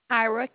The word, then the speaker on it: Kyra: Kyra, K-Y-R-A, Kyra.